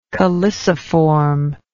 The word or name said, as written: calyciform